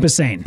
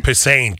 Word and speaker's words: Posehn: Comedian Brian Posehn? – Brian Posehn. – Posehn. God, I knew it. Posehn.